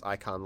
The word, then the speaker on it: Icahn: Lenore, you notice immediately that the only thing that could be described as Icon Laboratory is... It's spelled I-C-A-H-N.